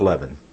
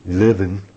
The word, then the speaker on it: Levin: Because all the conservative talk show hosts are getting together and they're backing Mark Levin. I'm sorry. Levin. Let me say it right.